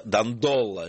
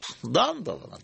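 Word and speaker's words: Дандоло: Итак, самое главное для меня была новость в том, что правильное ударение, что всю жизнь я ставил неправильное ударение в этой фамилии. Я всегда читал, что самым знаменитым дожем Венеции является Энрико Дандола. Нет, Дандола. – Дандола. Мои учителя тоже произносили Дандола.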